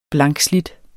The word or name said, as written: blankslidt